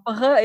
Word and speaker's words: Novembre: Novembre, yeah, I know that's hard. – Right. It's spelled November, but with the R and E switched. So yep. French version of November.